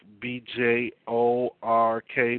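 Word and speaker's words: Björk: Thusly, you have those such as some who live in Iceland, Greenland, as I often point out, the famous singer, uh, her name is Bjork, B J O R K, but it's pro- pronounced Bjork. That should give some of you a hint into the, the language um, and dealing with the letter J.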